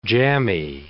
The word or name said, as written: jammy